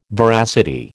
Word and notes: veracity: American English

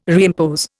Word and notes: reimpose: Indian

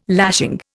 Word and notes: lashing: Indian

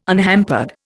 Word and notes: unhampered: Indian

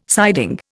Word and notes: siding: Indian

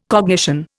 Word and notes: cognition: Indian

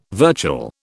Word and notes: virtual: British English

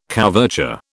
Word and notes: couverture: British English

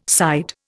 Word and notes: side: Indian

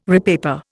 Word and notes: repaper: Indian

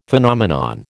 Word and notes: Phenomenon: American English

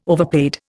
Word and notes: overpaid: Indian

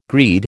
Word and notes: greed: American English

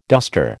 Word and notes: duster: American English

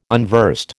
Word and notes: unversed: American English

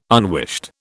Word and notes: unwished: British English

